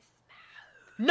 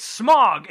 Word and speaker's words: Smaug: I just think it's funny they say Smaug. – No, but it's not Smaug. It's Smog. It's the Desolation of Smog. S-M-O-G is the way it's pronounced. – S-M-O-G? – Yes, yeah, Smog.